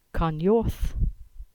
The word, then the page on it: Carnyorth: English